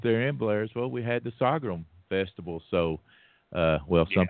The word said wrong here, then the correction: sorghum: people say I say it wrong. It's sorghum, but anyway, sorghum, sorghum.